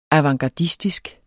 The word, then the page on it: avantgardistisk: ɑvɑŋgɑˈdisdisg; avɑŋ-